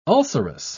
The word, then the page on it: ulcerous: British English